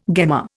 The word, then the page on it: gemma: Indian